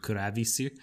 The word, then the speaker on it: Clavis: I know that in Japanese they pronounce his name Kurabisu.